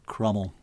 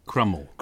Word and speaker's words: Cromwell: And then I thought, actually, if you pronounce that, it's really much more difficult than Cromwell. You try it sometime. And the likelihood is, in thinking of other Tudor pronunciations, it's Crummel.